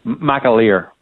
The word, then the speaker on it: McAleer: McAleer.